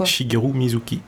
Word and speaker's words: Shigeru Mizuki: Tu prononces pas comme ça d'ailleurs, toi Shigeru Mizuki. Ah si, c'est bon.